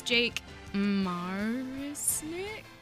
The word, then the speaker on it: Jake Marisnick: Astros on Thursday traded one of their outfielders, Jake Marisnick.